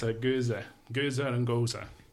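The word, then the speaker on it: Gose: Before you start, is it actually pronounced gosa? – It's like gosa? Gosa? – I thought it was goes. – Nah, so the E is not. The goose is the gers one. The one that's spelled like gooey-wee. That's gers. – The Belgian one, right? – Yes. – Yeah. – Gosa is a German like fruit beer.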